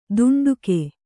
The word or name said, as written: ದುಂಡುಕೆ